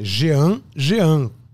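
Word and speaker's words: Jeanjean: É Jean Jean, se escreve assim.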